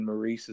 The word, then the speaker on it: Maurice: For some reason, Maurice is a little harder for people to pronounce.